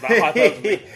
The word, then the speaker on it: hyperbole: But then, obviously, people work in massive hyperbole. – Too massive, hyperbole. by one syllable. – Hyperbole, even. And, yeah, it killed the British film industry, apparently, instead.